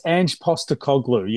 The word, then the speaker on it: Ange Postecoglou: Well, first of all, how was my pronunciation? Can you tell us exactly? Did I get that spot on? – Very close, Gav, very close. Ange Postecoglou. Yes, it's a wonderful traditional Greek name, Greek Australian name, and one that's very famous in this country.